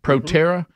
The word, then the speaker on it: Proterra: Proterra, which his own energy secretary was heavily invested. Of course, we'll tell you the end game on what just happened yesterday. – Let's see them praising Proterra.